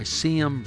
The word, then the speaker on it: Seyom: The book he recommends is one that he authored, New Forces, Old Forces, and the Future of World Politics by C.M. Brown. The first name is spelled S-E-Y-O-M.